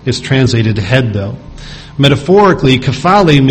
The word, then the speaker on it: kephale: That word head, used there, and as we just read in 1 Corinthians 11, is translated from the Greek word kephale. Kephale is spelled K-E-P-H-A-L-E, kephale. And the primary word that that's taken from, kapto, K-A-P-T-O, is actually is used or translated as a seizing or an embracing of something or someone.